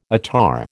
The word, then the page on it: attar: American English